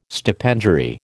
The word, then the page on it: stipendiary: American English